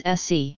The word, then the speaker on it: ACCE: The Blackstone complaints were organized by a group known as the Alliance of Californians for Community Empowerment, ACCE, pronounced by participants as SE.